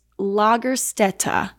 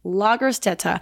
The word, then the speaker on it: Lagerstätte: and it is Lagerstätte, and it's spelled, I could have totally butchered that pronunciation. I Googled the pronunciation, that's what it said, but the way it's spelled is L-A-G-E-R-S-T-A with two dots over it, T-T-E, Lagerstätte.